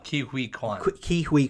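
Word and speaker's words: Ke Huy: I would love to see Ki-Hwa Kwan, if I'm pronouncing it right. – Ki-Hui Kwan.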